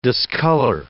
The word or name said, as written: discolor